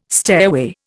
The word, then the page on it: stairway: Indian